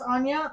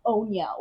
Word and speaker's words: Anya: I would be at, like, national swim meets, and they'd pronounce it Onya or Anya. I'm like, it's not even a hard name, and I'm at a regional competition, and you're going to pronounce my name wrong.